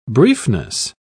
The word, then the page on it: briefness: British English